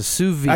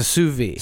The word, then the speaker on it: sous vide: Do you pronounce the D? – Nope. – Okay. – Or the I. – So it's a sous vide. – Actually, no, you don't, It's a sous vide. – Sous vide. Attaboy.